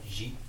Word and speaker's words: Xi: Xi.